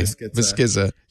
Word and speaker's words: Biskitza: Is it Biskitza? I'll say it right from now on. – I say Biskitza. I don't know what he says. – Oh, okay. Biskitza. Yeah, well, that's the correct Italian pronunciation.